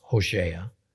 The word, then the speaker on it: Hosea: Now, in the book of Hosea, actually pronounced Hosea, chapter 1, we get a curious story about Hosea being ordered to marry a harlot, a prostitute named Gomer.